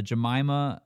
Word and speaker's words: Jemima: Jemima and Jemima, same spelling. Duh.